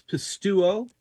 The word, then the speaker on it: pisteuo: Well, our next word is one that I don't think heard of before, and that is pisteuo. Did I say that correctly? – That's very close, pisteuo.